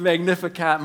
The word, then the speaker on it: Magnificat: It's called the Magnificat. Is that pronounced correctly? The Magnificat. Close enough. Thanks, man. The Magnificat.